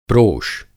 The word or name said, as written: प्रोष